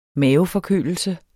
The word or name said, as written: maveforkølelse